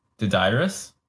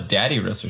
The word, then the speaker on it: Dodyrus: Wait, is that the pronunciation? – What? – Dodyrus? – That's how I always say it. – I thought it was Daddyrus or something. Yeah. I mean, I don't know why, I just thought it was more emphasis on the dad than the Dyrus part.